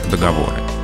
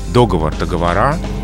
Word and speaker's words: договоры: Договор, договоры и допустимо в разговорной речи договор договора.